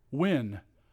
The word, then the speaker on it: Wynne: T-A-L-L-O-N. Way cool. I've never met a Talon before. What's your middle name? – Wynn. – How do you spell that one? – W-Y-N-N-E.